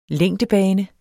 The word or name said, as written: længdebane